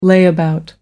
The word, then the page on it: layabout: American English